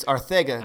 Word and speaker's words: Arcega: No, it's Arcega because that's how it's pronounced. It's pronounced like Mike Tyson would say it.